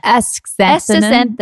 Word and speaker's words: astaxanthin: Astaxanthin.